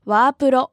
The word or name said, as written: ワープロ